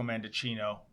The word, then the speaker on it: Mendicino: You know, Tamara, I have to say there is a similarity with Marco Mendocino, rhymes with Pinocchio, by the way, and Paul Bernardo.